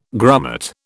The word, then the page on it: grummet: British English